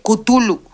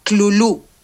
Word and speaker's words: Cthulhu: Y según Hexen, es Tulú. Mexicanizado y adaptado a nuestra propia interpretación, seguiremos llamándolo Tulú.